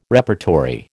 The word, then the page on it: repertory: American English